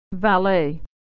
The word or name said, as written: valet